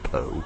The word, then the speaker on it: Pou: Luther spells his last name P-O-U, and for a while I thought the label had dropped, maybe Pouncy. But I found his obituary online, and it I think it's pronounced Poe.